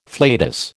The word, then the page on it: flatus: American English